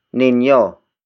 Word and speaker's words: ninyo: The next word is ninyo. Ninyo. Ninyo. Ninyo is spelled as N-I-N-Y-O.